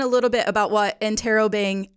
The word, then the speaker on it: Interrobang: So it's Interrobang? – We pronounce it Interrobang.